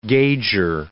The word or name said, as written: gauger